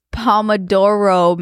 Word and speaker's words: Pomodoro: Is it Pomodoro? – It's Pomodoro method.